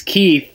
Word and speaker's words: Keith: You're Charmaine, and I'm Keith. – Oh, fuck. – It's Keith, but the H is silent.